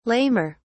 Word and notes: lamer: British English

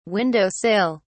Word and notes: window sill: British English

